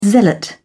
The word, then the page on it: zealot: British English